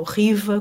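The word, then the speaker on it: Khiva: E, portanto, uma cidade que se chama eh, Kiva, ou Riva, como é pronunciado localmente,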